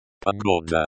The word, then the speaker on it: Погода: Погода. Emphasize the second syllable.